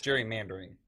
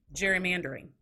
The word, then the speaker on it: Gerrymandering: Gerrymandering. – Gerrymandering.